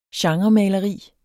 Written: genremaleri

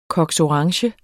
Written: cox

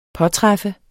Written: påtræffe

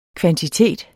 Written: kvantitet

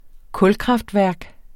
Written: kulkraftværk

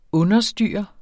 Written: understyre

